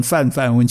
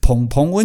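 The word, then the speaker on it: 芃芃: 蓬蓬很多以前都念成范范温泉，因为因为它草字头一个凡哈凡。那很多人念成这个范啊，其实它是音韵澎，对吧？天下杂志音韵澎的澎，所以澎澎温泉它是什么温泉？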